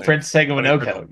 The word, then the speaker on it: Tagovailoa: Prince Tagovailoa.